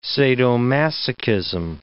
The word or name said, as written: sadomasochism